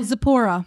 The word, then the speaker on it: Zipporah: Zipporah? Zippo? We're going to call her Zippo. – Oh, Zipporah. – Zipporah. Okay. – Yeah, I was going to say, wait, I know how to say it. I just have to see it. I couldn't find what verse it was Zipporah. – In. – Zipporah. – Yes. Zipporah.